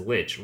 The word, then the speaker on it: lich: "With regards to how the word lich is pronounced, I typically pronounce it and hear it pronounced as lich, rhymes with witch.